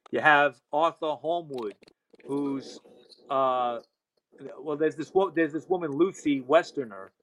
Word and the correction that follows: Westenra: Westenra, that's how you pronounce it.